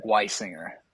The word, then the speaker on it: Weissinger: Weissinger. Weissinger.